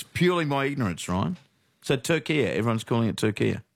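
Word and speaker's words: Türkiye: But in yesterday's Socceroos update, she kept saying Turkey and not Turkia.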